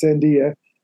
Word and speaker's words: Sandia: It is pronounced Sandia. That means watermelon in Spanish.